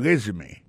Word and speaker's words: resume: I see from your resume. Mwen wè nan CV ou nan curriculum vitae ou, gade ki jen yo prononse mon résumé, asi fòm yon résumé, aksan tombe sou premier syllabe la.